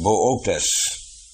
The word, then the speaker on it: Boötes: Er staat aan de sterren ook Boötes. Boötes, maar je herkent meteen Boats, want het moet met TS uitgesproken worden, Boats eigenlijk, Boats.